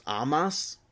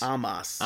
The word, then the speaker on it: Omos: They changed the pronunciation of Omos. It's now like Almas, but with an O. It's just Amas. – Amas? – Yeah. – Amas? – Amas. – Amas. So it's Almas minus the L.